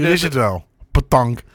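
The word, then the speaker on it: pétanque: Zaterdag in Veendal, de nationale hoe spreek je dat uit? – Petank. – Petank bij Le Sabon.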